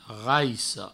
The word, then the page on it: raissa: provençal; raisso